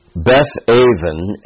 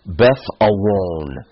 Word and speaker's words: Beth Aven: I remember talking about it at the Medills as we were meeting there, and I was speaking about it, that Beth Aven, as it gets transliterated, or Beth Awon, as it would be pronounced in the ancient Hebrew, is literally house of iniquity.